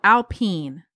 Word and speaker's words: Alpine: But now he's on the Alpine team in F1. But everything this guy touches business. Yes. – It's called Alpine? – Mm-hmm. That's correct. It's French. Yep. – Mm-hmm. It's called Alpine.